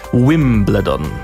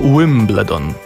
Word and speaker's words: Wimbledon: Zwracam Państwa uwagę na absolutnie tradycyjnie radiową i piękną wymowę nazwy tenisowego trawiastego wielkiego szlema w wykonaniu Adama. Wimbledon. Halo? Wimbledon. – To właśnie tak.